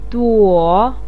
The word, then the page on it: duo3: pinyin